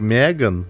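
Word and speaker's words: M3GAN: Значит, история такая, значит, 3 года назад выходил фильм, который называется «Мтриган», который на самом деле, ну, читается как «Меган», но это, блядь, модная еще в 90-х, мне кажется, хуйня цифры вставлять в эти в названия.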